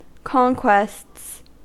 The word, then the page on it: conquests: American English